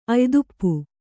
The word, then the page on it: ಆಯತಪ್ಪು: āyatappu